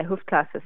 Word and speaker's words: Hoofdklasse: the Hoofdklasse, is that right? – Yeah, Hoofdklasse.